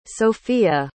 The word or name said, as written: sofiya